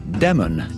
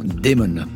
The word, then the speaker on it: Damon: Il faut bien dire Damon et non Damon, parce que Damon, ça veut dire démon, alors que Damon, bah, c'est bien le prénom. Vous me direz que Damon